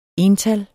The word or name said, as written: ental